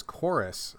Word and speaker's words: Chorvs: One thing that you didn't mention was one that I actually liked, was most interested in, was Chorus. But it's spelled with a V. It's like Chorvs, because they're getting all fancy, like, I don't know, like people do, I guess.